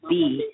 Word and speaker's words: Bea: Smooches to the artist, spoken word artist, that is B, pronounced B-E-A.